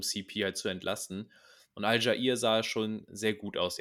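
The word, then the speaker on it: Allgeier: Allegary, wie spricht man ihn aus? Al-Jair. Al-Jair, danke.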